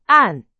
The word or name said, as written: 按